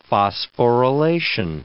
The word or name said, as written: phosphorylation